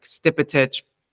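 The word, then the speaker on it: Stipetić: So my last name is Stepetic, it's Croatian. That is not how you pronounce it in Croatia. So like, it was only talking to my grandfather that I even know how to like pronounce my last name, like Stipetic, correctly.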